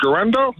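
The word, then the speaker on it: Guerendo: But is it Garendo that you said? – Yep.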